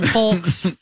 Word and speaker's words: folks: Folks. – Folks, and yolks. – It's mouthy. – Yeah. – Folks. You're mouthy. – Yeah, because you don't pronounce the L.